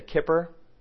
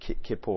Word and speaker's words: Kippur: I always want to say kipper, that's how I want to pronounce it. That's not right. Don't say that. But I think it's Kippur or something like that.